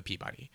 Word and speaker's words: Peabody: the whole staff wins the Peabody. – Yeah. – So that's I say Peabody because I'm from the North Shore of Massachusetts, where there's a town spelled the same as Peabody but pronounced Peabody.